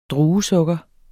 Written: druesukker

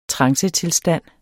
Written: trancetilstand

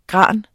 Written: gran

